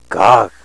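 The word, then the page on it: gaag: Nishnaabemwin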